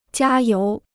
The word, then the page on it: 加油: jiā yóu